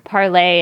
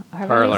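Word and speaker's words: Parler: And there were a lot of warnings on Parlay and Gab. – Parlor.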